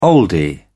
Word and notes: oldie: British English

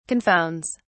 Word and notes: confounds: British English

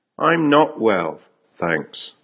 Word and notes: I'm not well, thanks: American English

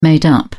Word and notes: made-up: British English

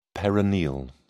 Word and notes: peroneal: British English

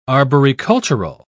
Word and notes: arboricultural: British English

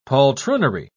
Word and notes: poltroonery: British English

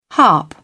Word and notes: harp: British English

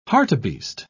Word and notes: hartebeest: British English

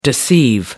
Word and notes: deceive: American English

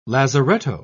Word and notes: lazaretto: British English

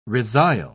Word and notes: resile: British English

